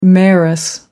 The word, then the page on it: mayoress: American English